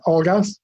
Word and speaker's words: algas: And you mentioned one example that we developed, which is uh, a project we called All Gas, because it's based on algae, which in Spanish you would produ- pronounce algas, and it also converts wastewater to gas, which is, of course, an energy source that can even fuel your car.